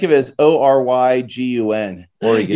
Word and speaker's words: Oregon: Being from Portland, and we pronounce it Oregon, by the way, not.